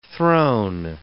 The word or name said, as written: thrown